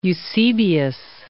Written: Eusebius of Caesarea

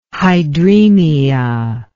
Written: hydraemia

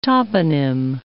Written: toponym

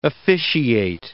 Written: officiate

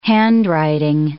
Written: handwriting

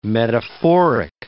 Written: metaphoric

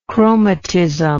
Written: chromatism

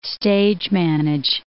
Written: stage-manage

